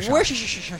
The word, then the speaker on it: Worcestershire: How do you pronounce that? – Worcestershire. Worcestershire.